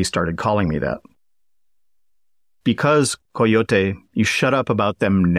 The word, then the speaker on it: coyote: Coyote. But pronounced the correct way, Coyote.